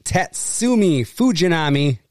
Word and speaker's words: Tatsumi Fujinami: 1988, Tatsumi Fujinami.